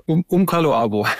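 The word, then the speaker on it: Umckaloabo: Du hast ja vorhin gerade das Beispiel von Cola Luabum, ich weiß gar nicht, ob ich es jetzt richtig ausgesprochen habe. – Umkaloabo.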